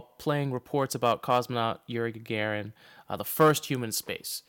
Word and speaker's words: Gagarin: G-A-G-A-R-I-N.